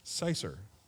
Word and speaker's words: cyser: How do you pronounce that? Cicer? C-Y-S-E-R. Cicer.